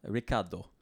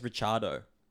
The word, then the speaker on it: Ricciardo: Well, obviously him being Australian, he pronounces it Ricciardo. – That's my name. That's the Italian pronunciation.